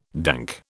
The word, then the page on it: dank: British English